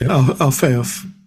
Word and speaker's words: Alfeyev: I always think of Metropolitan Hilarion Alfeyev. I don't know if I said that right, but Alfeyev.